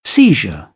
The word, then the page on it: seizure: British English